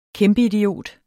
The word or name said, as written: kæmpeidiot